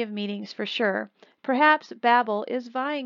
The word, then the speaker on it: Babel: Babylon is three Hebrew letters, Beit, Beit, Lamed, and is pronounced Babel.